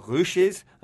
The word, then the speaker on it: Rouches: Standard players are nicknamed the Rouches because of their red shirts. Now, this is a corruption of the French word rouge, which is pronounced with a Liège accent, and it sounds like rouche.